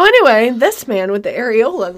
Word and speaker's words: Arreola: It says Areola, but A-R-R-E-O-L-A, Areola. – Mine were just out. – That's so weird. – Spell it again. – A-R-R-E-O-L-A, Areola.